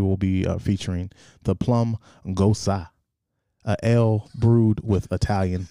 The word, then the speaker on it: Gose: pronounced G-O-Z-U-H, Gosa.